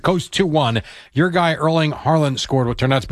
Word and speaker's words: Haaland: It's Haaland.